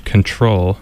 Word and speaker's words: CTRL: Control, CTRL, like the key on a computer keyboard, and that album is from 2017.